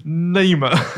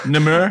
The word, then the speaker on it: Namur: And the other one would obviously be Namur. How do you pronounce it? – Namur. – Namur.